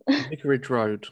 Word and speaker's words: Vicarage Road: Vicarage Road.